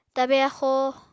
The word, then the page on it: tabe axóo: Apatani